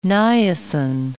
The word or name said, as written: niacin